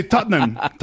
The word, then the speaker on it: Tottenham: Nosotros los británicos, pero solo dos sílabas. – Sí, Tottenham.